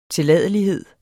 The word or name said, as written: tilladelighed